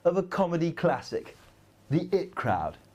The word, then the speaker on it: The IT Crowd: I always used to call it the IT crowd, but I saw a behind-the-scenes clip with the producer where he pronounced it as thus.